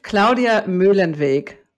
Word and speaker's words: Muehlenweg: Tell me how to say it exactly right, Claudia. – First of all, I'm so glad to be here. Claudia Muehlenweg. – Muehlenweg. So I almost had it.